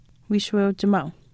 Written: wiishuweuchimaau

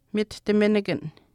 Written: mitihtiminikan

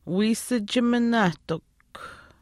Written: wiisichiminaahtikw

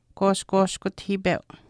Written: kwaashkwaashkutahiipeu

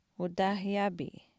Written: utaahaayaapii

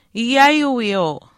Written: iyaayiwiiu

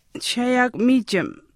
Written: chishaayaakumiichim

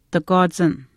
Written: takwaachin

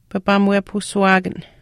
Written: papaamuwepahusuuwaakan

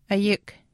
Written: ayikw

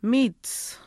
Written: miitus

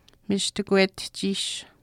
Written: michishkwetihchiish

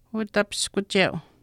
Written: utapiskucheu

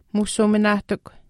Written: muusuuminaahtikw

